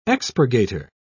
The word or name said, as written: expurgator